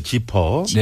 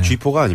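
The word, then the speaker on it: Zipper: 지퍼라는 팀으로 활동했을 때 노래고 또 아까 발음을 그- 잘해야 되겠네요. 지퍼. 네. 쥐포가 아닙니다. 네, 지퍼. 네.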